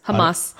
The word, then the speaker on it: Hamas: Is it called Hamas? – Yes. Hamas.